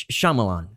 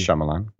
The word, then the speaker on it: Shyamalan: It's Shyamalan. So we've gone on too long. We should probably. – M. Night Shyamalan.